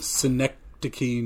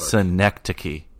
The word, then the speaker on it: Schenectady: Schenectady, New York. – Schenectady, New York.